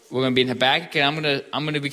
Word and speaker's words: Habakkuk: And you'll notice I pronounce it Habakkuk. We'll see how the other guys pronounce it as the weeks go on. That's my pronunciation of choice.